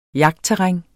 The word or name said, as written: jagtterræn